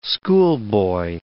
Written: schoolboy

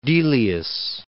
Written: Delius

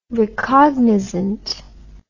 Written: recognizant

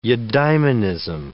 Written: eudaimonism

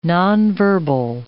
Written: nonverbal

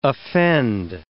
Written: offend

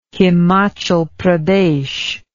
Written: himachal pradesh

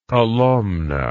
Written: kolomna

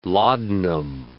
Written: laudanum

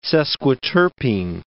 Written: sesquiterpene

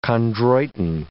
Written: chondroitin

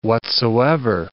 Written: whatsoever

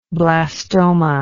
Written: blastoma

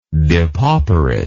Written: depauperation

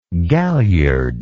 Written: galliardly

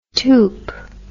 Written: toup

